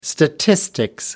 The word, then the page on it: statistics: British English